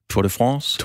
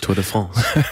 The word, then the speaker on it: Tour de France: Og så starter vi endda med, at, øh, at Thomas Sand fortæller, at øh, Tour de France, som Thomas jo meget, meget korrekt udtalte, det er vi glæde, Jørgen lidt, Tour de France, Tour de France.